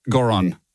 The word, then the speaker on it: Goron: Goron. – G-O-R-O-N. – G-O-R-N. – G-O-R-O-N. G-O-R-O-N.